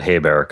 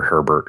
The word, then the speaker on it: Hebert: First of all, I'm glad you said Hebert because I think I've always pronounced his last name Hay or Herbert or something like that.